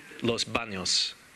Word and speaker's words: Los Baños: Uncle Frito's Chuckle Hutch in Los Baños. I did say it right, didn't I? Los Baños, like where is the baño?